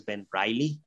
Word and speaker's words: Ben Reilly: Ben Reilly, no en esa época yo le decía Ben Reilly, pero pues la pronunciación es Ben Reilly.